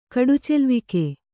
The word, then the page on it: ಕಡುಚೆಲ್ವಿಕೆ: Kannada